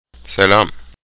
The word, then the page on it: selam: Turkish